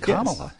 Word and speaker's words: Kamala: Hey, you pronounced her name right. He said Kamala.